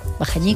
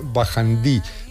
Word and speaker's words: Bajañí: También a veces se escribe y se pronuncia Bajandí, pero bueno, creo que aunque se escribía Bajandí, también se seguía pronunciando en Bajañí.